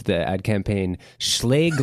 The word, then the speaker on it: Schlage: pronounced Schlage like plague.